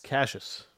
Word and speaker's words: Cassius: Was it Cassius or Cassius? – Cassius. – Cassius Ohno. – Cassius Ohno. Now we know how to say it properly.